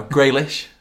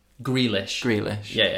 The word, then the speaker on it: Grealish: No, Graylish. – Grealish.